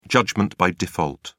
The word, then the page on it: judgment by default: British English